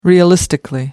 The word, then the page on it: realistically: American English